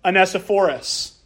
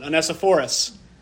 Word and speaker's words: Onesiphorus: In verse 19, again, it says, "Greet Prisca and Aquila and the household of Onesiphorus." I looked up how to pronounce that word, and that's how they said to pronounce it. Onesiphorus.